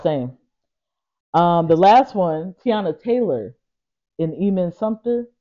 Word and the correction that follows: Iman Shumpert: Shush. Wait. Iman Shumpert? – Yeah. Did I say his name right?